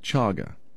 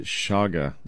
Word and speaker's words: chaga: Chaga. At least that's how I say it. Maybe some people pronounce it shaga.